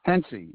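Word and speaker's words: Haentze: Hensy. You just dropped the A.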